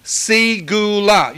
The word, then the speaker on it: segula: It's the word, listen, segula. You can spell it C-E-G-U-L-A or S-E-G-U-L-A, whichever way it comes out correct, segula, in your mind.